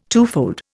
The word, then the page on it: twofold: Indian